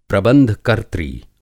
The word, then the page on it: प्रबंध कर्त्री: Hindi